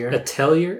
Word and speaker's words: Atelier: Atelier.